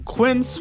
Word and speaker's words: Quince: Today Explained's support today comes from Quince, which rhymes with since, but is spelt with a Q-U.